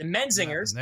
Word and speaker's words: Menzingers: But the Mogwos, ladies and gentlemen, if you ever heard of them, they remind me of the Metzingers, and they also, I didn't pronounce all right, the Menzingers. – There you go.